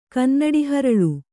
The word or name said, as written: ಕನ್ನಡಿಹರಳು